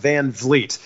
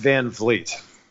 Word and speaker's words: Van Vliet: I must say, though, my last name is pronounced Van Vliet. It's spelled funny, I know, but it's pronounced Van Vliet. – That's why it rhymes with meat.